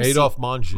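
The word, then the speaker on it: Adolphe Menjou: He made the veteran actor Adolf Menjou do the same... – Adolf Manju. – Manju, I knew I was saying that wrong.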